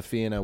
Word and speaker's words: Fianna: And all of that does happen, especially that the Fianna, how it's said, is the Fianna will not destroy beauty or destroy a woman's beauty, which is quite problematic and is another part that hasn't aged well.